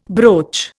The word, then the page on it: broach: Indian